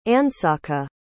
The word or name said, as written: andsaca